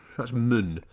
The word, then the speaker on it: Scratchman: Scratchman, or is it the Scratchmen? – Mun. That's how they pronounce it. – Scratchmen.